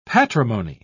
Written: patrimony

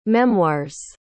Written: memoirs

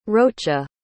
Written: rocha

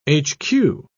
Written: hq

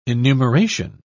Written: enumeration

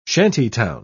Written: shantytown

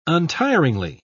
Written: untiringly

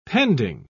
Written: pending

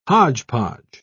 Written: hodgepodge

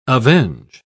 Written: avenge

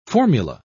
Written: formula

